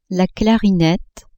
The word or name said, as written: clarinette